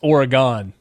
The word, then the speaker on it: Oregon: No, it's Oregon. – Sorry. – Not Oregon.